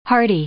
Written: hearty

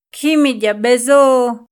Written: Kjimidya b'ezo

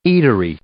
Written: eatery

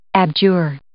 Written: abjure

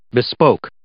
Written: bespoke

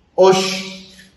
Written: óox

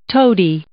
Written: toady

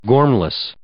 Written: gormless

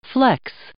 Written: flex

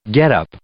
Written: getup

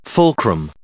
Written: fulcrum

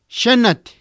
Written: xanat

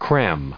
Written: cram

